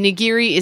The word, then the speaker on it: nigiri: So nigiri.